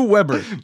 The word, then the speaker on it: Weber: Rule number one: you can only use one species of agave, world famous Tequilana Blue Beber. Rule number two: Blue Weber. – Blue Weber. Well, I think it's pronounced Weber because it's in German, no? And W is pronounced as B in German.